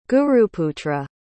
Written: guruputra

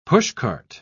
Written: pushcart

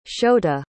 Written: shouta

